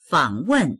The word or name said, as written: fang3wen4